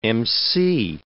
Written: MC

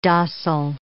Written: docile

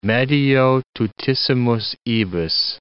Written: medio tutissimus ibis

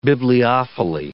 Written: bibliophily

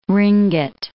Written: ringgit

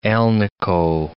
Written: alnico

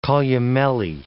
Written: columellae

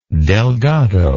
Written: delgado

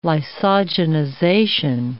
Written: lysogenization

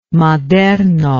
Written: Maderno

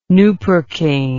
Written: nupercaine

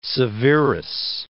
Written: Alexander Severus

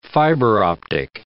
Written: fiber-optic

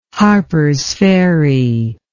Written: harpers ferry

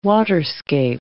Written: waterscape

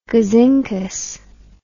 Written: gazinkus

